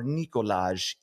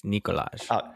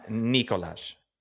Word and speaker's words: Nikolaj: Jonathan Huberdeau at pick 80 or Nikolaj Ehlers? What say you? – You said it wrong. It's Nikolaj. – Nikolaj.